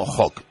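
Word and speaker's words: Höch: se escribe Och con H y, con CH y con una diéresis encima de la O, digo por quien quiera buscarla y esté interesado. Pero aquí lo vamos a pronunciar como Hock, y no se hable más.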